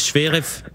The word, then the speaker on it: Zverev: udtaler jeg hans navn rigtigt? Zverev? – Ja, jeg tror ikke, det er sådan en SCH-lyd. Det er jo en sæt.